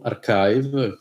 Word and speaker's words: arXiv: E poi sì, se volete contattarmi, scrivetemi, basta che cercate il mio nome su Archive, che è stato, che si scrive A-R-X-I-V,